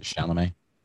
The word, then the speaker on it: Chalamet: Is that how you say it? Chalamet? – Yeah.